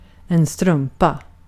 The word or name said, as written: strumpa